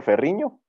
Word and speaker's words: Ferrigno: Eh, pues mi nombre es Héctor Ferriño. ¿Se pronuncia Ferriño? – Eso.